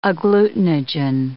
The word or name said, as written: agglutinogen